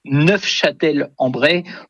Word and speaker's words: Neufchâtel-en-Bray: Et en effet, pour les puristes, dont je ne suis pas, puisque je n'ai pas prononcé le F, mais pour les puristes, en effet, il faut prononcer Neufchâtel-en-Bray pour, en effet, peut-être se différencier de la ville de nos amis suisses.